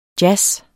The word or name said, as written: jazz